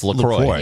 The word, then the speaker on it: LaCroix: It's LaCroix.